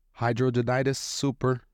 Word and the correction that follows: hidradenitis suppurativa: Suppurativa. – Suppurativa. Hidradenitis suppurativa.